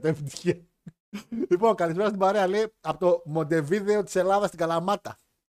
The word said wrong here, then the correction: Μοντεβιδέο: Μοντεβιδέο. – Να, τονίζει, να τονίζει σωστά ο φίλος εδώ ο Νόσχαν.